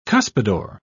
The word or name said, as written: cuspidor